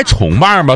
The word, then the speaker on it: 重瓣: 那不应该重瓣吗？多重瓣吗？ – 但不是，就是它的标准读音读重瓣玫瑰。